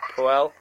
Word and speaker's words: Puel: Leicester City have appointed former Southampton boss Claude Puel. I said that right? – Puel.